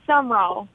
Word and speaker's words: Sumrall: Tell me how to pronounce the city name. Let's see who wins. – It's Summerall. – Okay.